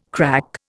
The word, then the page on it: crack: Indian